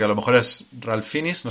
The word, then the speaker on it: Fiennes: O sea, Fines es Fines, como de, toda la vida, Fines.